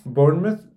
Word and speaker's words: Bournemouth: Bournemouth.